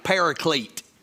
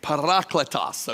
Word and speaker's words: parakletos: That's how you would say it in Greek, unless you're Southern and you try to say Greek things, and then you say paraclete okay? Okay? It's parakletos, okay, is actually how you say it in Greek.